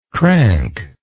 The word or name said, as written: crankless